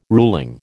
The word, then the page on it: ruling: American English